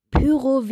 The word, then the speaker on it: Pyro: Sorry, aber Pyro wird mit Ü ausgesprochen und steht für Py Pyrotechnik, Feuerwerk.